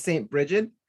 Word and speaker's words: Bridget: And one of the people who I was very excited to read about was St. Bridget.